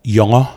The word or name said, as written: Yawngaw